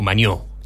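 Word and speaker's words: Mañó: Juan Luis Jiménez y Nacho Maño. O Maño, tiene acento en la O, Maño, Nacho Maño.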